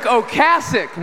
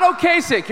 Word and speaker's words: Ocasek: Rick Ocasek, not Ocasek, you're all saying it wrong.